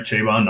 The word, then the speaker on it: Chabon: So that's interesting, too, because there was an interview with Michael Chabon, who we've said his name wrong all season of our show, so we now are saying it right, Chabon, not Chabon, or whatever I was saying.